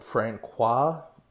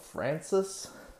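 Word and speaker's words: François: So the one person's name, it's spelled Francois, F-R-A-N-C-O-I-S. They keep on calling him Francis.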